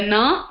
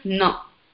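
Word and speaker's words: Na (न): The Na that you pronounce by touching the tip of your tongue on the back of your upper teeth. So this is like this. This is Na.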